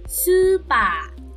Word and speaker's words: スーパー: Okay, so please make sure that you enunciate this properly, because supa is supermarket with a long u and long a sounds, while supa, like supa, okay, it has just a long a sound but not a long u sound. That would be your spa, as in the place where you get your massage.